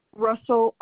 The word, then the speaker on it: Russell: Russell, RUSSELL, last name FLATH.